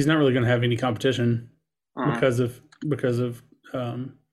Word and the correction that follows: Chudak: Is that how you say his name? – Chudak.